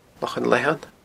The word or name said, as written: Lochan Leathann